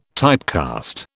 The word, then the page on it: typecast: British English